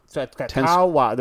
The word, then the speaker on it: Tenskwatawa: Tenskwatawa. – There's that extra W.